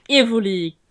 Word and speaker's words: Evoli: Genau, also Evoli. Habe ich es richtig ausgesprochen? – Ja.